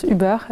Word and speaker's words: Uber: Mais tu vas être un puriste, en fait, Uber, ça vient de l'allemand, ça se prononce Uber, donc on dit Uber.